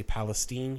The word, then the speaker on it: Palestine: They had to move to Palestine, Texas, which is spelled like Palestine, but we say Palestine here in mm-hmm.